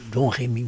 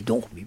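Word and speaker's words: Domrémy: Alors, est-ce que vous pouvez nous préciser la situation Alors, le village de Dormi de je crois pas qu'il faut pas prononcer le Donremy, mais Dormi.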